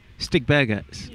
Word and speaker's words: Stigbergets: I'll probably mispronounce this. Stigberts? – Yeah. – Swedish, I think. – Yeah. Stigbergets.